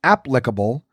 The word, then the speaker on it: applicable: The first syllable is the one that should be emphasized, as in applicable, rather than the second, which is applicable.